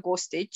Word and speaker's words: Gostic: So if you Google my name, Dr. Monika Gostic, you will find me.